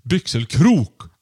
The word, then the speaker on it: Byxelkrok: Byxelkrok uttalas med betoning på sista stavelsen. Byxelkrok.